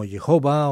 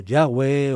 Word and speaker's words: Yahvé: Este nombre que se pronuncia como Jehová o Yahweh o Yahvé significa en términos generales el eterno, el Dios que está más allá del límite del tiempo.